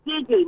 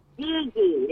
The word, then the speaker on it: Geegee: Gigi. My grandchildren call me Gigi. – Gigi. – Gigi. – All right, Gigi. – No, actually it's Gigi. They call me Gigi. – Spell it for us, dear. – Well, I would say G-E-E-G-E-E. – G-E-E-G-E.